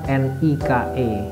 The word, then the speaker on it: Nike: merek Nike itu tulisannya hurufnya kan N I K E tapi dibaca Nike ya